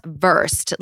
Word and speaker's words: Verst: Verst is pronounced Verst, like V-U-R-S-T, as in well-versed.